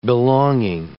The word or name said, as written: belonging